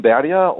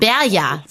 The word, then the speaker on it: Berger: Berger, wird er tatsächlich ausgesprochen. – Eigentlich heißt es Sam Berger und Molly Bloom. – Berger, so. Naja, genau. Das Schwedisch, ja.